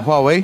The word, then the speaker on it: Huawei: A pronúncia certa é essa? Huawei? – Huawei, não é? – Huawei.